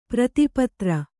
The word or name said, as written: ಪ್ರೀತಿಪಾತ್ರ